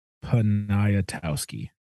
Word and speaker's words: Poniatowski: Crap. Poniatowski. – Poniatowski. – Poniatowski. – Do that again. – Poniatowski, I think. – Poniatowski. – Poniatowski. – Yeah, the last two syllables are like the last seven letters.